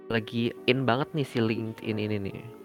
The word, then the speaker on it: LinkedIn: Bacanya LinkedIn. LinkedIn.